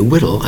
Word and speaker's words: whittle: They whittle a piece of wood. That's right. The word whittle has two distinct syllables, spelled W-H-I-T-T-L-E.